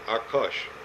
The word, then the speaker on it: Arkos: It's A-R, it would be pronounced properly Arkos.